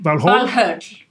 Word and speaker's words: Valhöll: One of the things that on the internet searches, people just seem desperate to know about is Valhalla, which should be pronounced Valhall. – Valhöll. – Okay. – Yeah, in Iceland, in modern Icelandic.